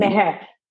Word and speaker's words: Meher: And it's Mihar and Me. Is that right? Pronounce it correctly. – Meher. Correct.